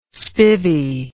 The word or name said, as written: spivvy